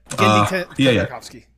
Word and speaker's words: Tartakovsky: Like, Gendy Tartakovsky? – Yep. Tartakovsky.